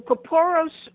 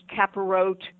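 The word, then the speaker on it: Kapparot: Well, Kaporos is, and as you said, it can also be pronounced kaparot.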